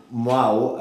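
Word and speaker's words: Mahou: Yeah, so Mau, as I'm told it's pronounced, is like a Spanish beer.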